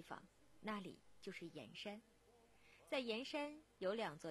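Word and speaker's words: 铅山: 我们的脚步来到江西盐山古镇。说到盐山，其实盐字就是铅笔的这个铅字，在这里呢要读成盐。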